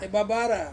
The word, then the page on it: babara: Ansus